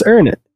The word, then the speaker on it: Uranus: But yeah, it's pronounced Uranus. Uranus.